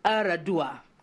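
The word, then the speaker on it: Yar'Adua: Umaru Musa, and then the last name, Eradua. Now, it's spelt with a Y, Y-A-R apostrophe capital A-D-U-A, but it's pronounced almost without the Y. You swallow it. So Umaru Musa Eradua.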